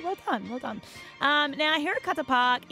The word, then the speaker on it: Hirakata: This theme park is called Hirakata Park. It's in Osaka. – Is that how you pronounce it? – Yeah, I did Japanese for my leaving exams.